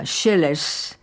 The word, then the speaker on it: Sileas: El dúo Sileas, que se pronuncia Sheles y que también es dúo de arpas, interpreta este Patsabul.